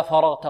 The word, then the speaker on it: فرغت: إذن هنا قلنا نقول فرغت، فإذا فرغت فاء، لا فاء، فرغت، انطقوا معي فرغت، ولا نقول فرغت فاء، نقلل قليلا، لا فاء، فاء، فرغت.